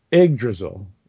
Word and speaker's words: Yggdrasil: I think it's pronounced, no, J is pronounced as Y, I'm sorry, and it is the letter Y here, so it would be Yggdrasil, I guess, which is, like you said, the tree of life.